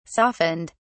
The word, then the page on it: softened: British English